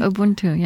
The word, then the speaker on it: Ubuntu: the virtue of Ubuntu. Am I pronouncing it correctly? – Oh, yeah, Ubuntu, yeah. – Ubuntu.